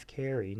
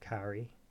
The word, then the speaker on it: Carey: Like, if you talk about awards, they were pushing Carey Mulligan, sorry. I've only recently realised I've been saying that wrong the whole time. It's Carey, not Carrie. Carey Mulligan.